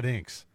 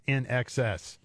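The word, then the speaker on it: INXS: He said, "Here's a new one from Inx." And I went into the studio. I said, "Dude, it's not Inx. It's NXS."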